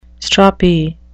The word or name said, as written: stroppy